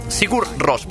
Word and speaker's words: Sigur Rós: Es diuen Sigur Rós, però és que es pronuncia d'una manera molt especial, perquè està dit en islàndic, i és així, si se sent. Vale, s'ha sentit superplus. Segostos, o algo així.